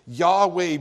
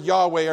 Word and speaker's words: Yahweh: And when you understand the consonants in the word Yahweh, the Hebrew consonants in the word Yahweh are the only Hebrew consonants that do not allow your mouth to actually move. It must remain open, and your tongue does not move when you say it correctly. When you pronounce his name, it is essentially breath.